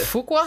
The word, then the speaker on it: Fuqua: It's spelled F-U-Q-U-A. Fuck out. Fuck out of here. – No, I don't know how to pronounce his last name no more because you said that. – Fuqua? – Fuqua. There you go.